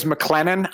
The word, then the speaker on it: McLennan: My last name is McLennan. I don't know how many times people are like McClendon, McClellan. Like, it just they butcher it. McLean. Like, it's painful.